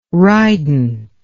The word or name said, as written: rident